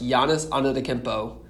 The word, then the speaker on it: Giannis Antetokounmpo: It's Giannis Antetokounmpo.